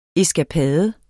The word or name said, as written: ESKAPADERNES